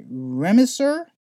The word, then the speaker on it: Remdesivir: So the FDA has officially approved, and let me make sure I say this right, Remdesivir, spelled R-E-M-D-E-S-I-V-I-R, to treat COVID-19.